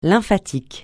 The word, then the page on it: lymphatique: francais